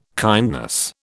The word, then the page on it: kindness: British English